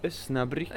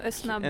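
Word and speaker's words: Osnabrück: Vi har kommit till eh, Ösnabruk. – Med... Eh, vet inte om, vi uttal, om det uttalas så. – Ösnabrück. Ösnabrück. En väldigt fin tysk liten stad.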